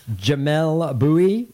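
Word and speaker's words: Jamelle Bouie: Jamel Bowie.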